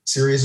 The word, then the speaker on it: Cereus: Sirius is spelled C-E-R-E-U-S. And Limnic, spell it how it sounds, L-I-M-N-I-C.